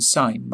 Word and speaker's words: sign: G in sign must silent be. In signature, pronounce the G.